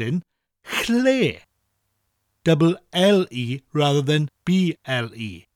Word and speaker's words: Lle: Chle is spelt L-L-E, and that double L sound is often hard for people to say when they first start learning Welsh. Well, I'm going to show you now how to say it nice and easily. To say it, put your tongue on the roof of your mouth just above your teeth and say L like the L in lid, L, while blowing air.